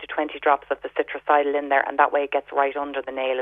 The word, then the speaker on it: Citricidal: So it's sold under the name Citricidal um, by a company called Higher Nature. They do a liquid one, um, and that's spelled C I T R I C I D A L, citricidal it's a very good natural antifungal.